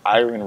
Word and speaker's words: Iren: My Instagram is Iren Rainey, just my first and last name is spelled I-R-E-N, last name Rainey, spelled R-A-I-N-E-Y.